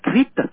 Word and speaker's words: Crete: tiếng Anh đọc là Crete.